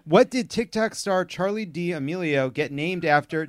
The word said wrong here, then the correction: D'Amelio: Did I say that right? – What? D'Amelio.